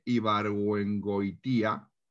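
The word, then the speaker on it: Ibargüengoitia: Y esto es una, frase que escribe el escritor mexicano Jorge Ibarbu, a ver si lo digo bien, ¿vale? Jorge Ibargüengoitía, ¿vale?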